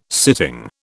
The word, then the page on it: sitting: British English